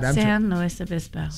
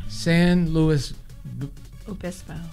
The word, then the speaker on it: San Luis Obispo: Say the name of the city again. You said it right. – San Luis Obispo. – San Luis Obispo.